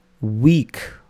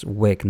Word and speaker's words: Wiik: I think it should be pronounced as Wick, not Week. There are two I's, in fact, W-I-I-K. So I was thinking it should be Week, but Wick sounds better.